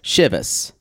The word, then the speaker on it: Chivas: Always heard it as Chivas. – Chivas. – Maybe that is, that's for me working in bartending. Maybe it's because it's in the South or Michigan. Heather says Chivas. Like it rhymes with Shiraz.